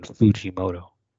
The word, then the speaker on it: Fujimoto: You would pronounce the U in Fujimoto.